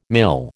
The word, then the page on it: mil: American English